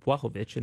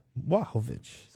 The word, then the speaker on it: Błachowicz: And I've had people ask why I pronounce it Błachowicz, and that's because that's how he told me it was pronounced. – Błachowicz.